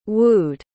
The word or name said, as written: wooed